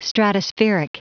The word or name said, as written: stratospheric